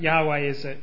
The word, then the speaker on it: Yahweh: But in the original Hebrew, it doesn't have vowels, which makes it quite hard to pronounce. And so some people have added some vowels in and called it Jehovah. But Yahweh is a way we can pronounce it.